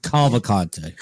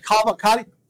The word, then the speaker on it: Cavalcante: Di Cavalcante? – Cavalcante. – Cavalcante.